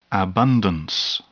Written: abundance

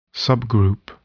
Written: subgroup

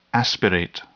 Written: aspirate

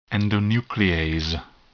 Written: endonuclease

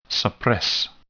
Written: suppress

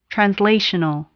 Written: translational

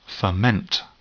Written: ferment

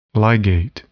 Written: ligate

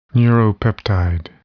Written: neuropeptide

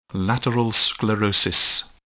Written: lateral sclerosis

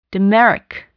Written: dimeric

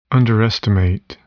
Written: underestimate

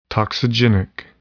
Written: toxigenic

